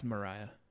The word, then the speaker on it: Myriah: It's Mariah. – Mariah to me is spelled with an A, but it's Myriah.